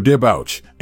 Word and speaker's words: debouch: The word's French origins are reflected in its pronunciation, which features a soft SH sound at the end. There are several synonyms for debouch, including emerge, issue, and flow out.